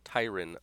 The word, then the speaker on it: Tyrann: It's like Byron. Tyrann, like Byron.